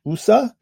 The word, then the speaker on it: oseh: It's pronounced USA.